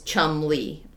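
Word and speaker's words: Cholmondeley: And the surname's pronunciation is Chumley, like two syllables.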